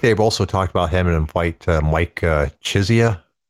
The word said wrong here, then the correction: Chiesa: I'm saying his name wrong, but you know who I'm referring to. – Yes. Chiesa? – Wow, is that how you say it? – Yes.